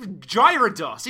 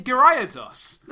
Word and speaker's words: Gyarados: You just Gyarados. It's Gyarados. – No, it's an extra syllable.